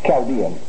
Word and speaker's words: Chaldean: So here's a word. Chaldean is really the correct pronunciation.